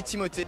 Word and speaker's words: Timothée: My name is Timothy Chalamet. – Timothy. – Timothy. Timothy. – Chalamet. It's supposed to be Timothée. Timothée Chalamet.